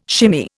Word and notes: shimmy: Indian

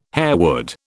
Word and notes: harewood: British English

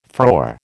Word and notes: frore: American English

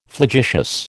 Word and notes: flagitious: American English

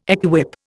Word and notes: equip: Indian

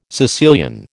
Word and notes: Sicilian: American English

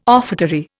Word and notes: offertory: Indian

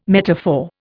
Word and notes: metaphor: Indian

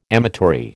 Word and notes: amatory: American English

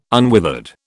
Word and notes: unwithered: British English

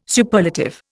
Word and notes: Superlative: Indian

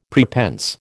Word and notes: prepense: American English

